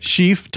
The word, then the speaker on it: Schief: My last name is spelled S-C-H-I-E-F, like Chief with an S on the front. Just go to Ed Chief, type that into YouTube or into, you know, Pandora or however you stream music, iTunes, Spotify.